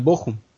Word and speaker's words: Bochum: En la Bundesliga 2 tenemos en primer lugar al Bochum, así se pronuncia, doctor. – Al Bochum.